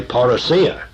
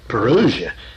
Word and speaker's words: parousia: If I'm talking about the Greek word, I'll say parousia, and put the emphasis upon the last syllable where I feel it should be. But if somebody wants to use the English form that you can find in the dictionary and say parousia, that's all right with me.